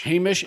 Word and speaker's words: Hamish: And then you get to episode three, after they introduce, is it Hamish Linklater? Is that how you say it? – I think so. – Yeah. – Oh, actually, I think it's Hamish. – Hamish. Hamish sounds more yeah.